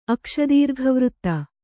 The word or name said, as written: ಅಕ್ಷದೀರ್ಘವೃತ್ತ